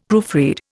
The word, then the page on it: proofread: Indian